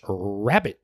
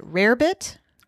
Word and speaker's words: rarebit: Rarebit, yes, but you can pronounce it either way.